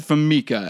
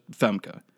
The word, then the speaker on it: Famke: So you said Famica, and I was like, that... There's no other E in there for Mika. It's just Famke. So I looked it up, and it's just Femke.